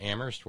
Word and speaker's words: Amherst: And Terry and Amherst, where only the H is silent, and other members of the Horn Family Community Congregation in the greater New England area, Darlene in Connecticut, pay attention.